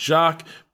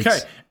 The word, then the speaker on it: Jacques: The amount of information displayed is very nice, but more importantly, the commentary team of Alex Jacques, pronounced okay, Jakes. Alex Jakes, and Alex Brundle, LMP2 driver and son of Martin himself, really won me over.